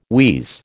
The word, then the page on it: wheeze: American English